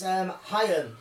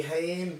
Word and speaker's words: Haim: Like, so, okay, so yeah, it's Haim, like, which is spelled H-A-I-M, like Corey Haim, like, but yeah, it's Haim.